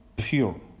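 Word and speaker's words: pure (πῦρ): It's actually spelled P-Y-R. But it's pronounced pure, like P-U-R. But it's Greek.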